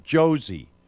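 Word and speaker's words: Jozy: It's not Jose. It's pronounced Josie. Seriously.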